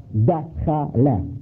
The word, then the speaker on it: dakhala: The Arab pronounced entered like went in that door, dakhala, dakhala, dakhala. They say dakhala, you see?